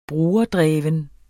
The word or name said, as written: brugerdreven